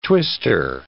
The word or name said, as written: twister